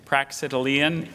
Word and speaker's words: Praxitelean: Praxitelean is the only pronunciation I have.